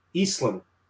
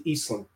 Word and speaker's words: Eiesland: Her name is Nancy Eiesland.